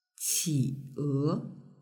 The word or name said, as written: qie